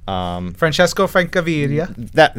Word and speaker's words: Francesco Francavilla: Francesco Francavilla.